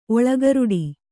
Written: ಒಳಗರುಡಿ

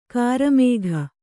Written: ಕರಿಮುಖ

